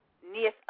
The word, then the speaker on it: Neith: If you want to pull Neith up, her name is spelled N-E-I-T-H. It's spelled like Keith, but it's with an N instead of a K.